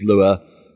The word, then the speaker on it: Lua: Lua. – Lua. – And we chose that because it's something that there are Minecraft mods that let you use Lua to then program Minecraft.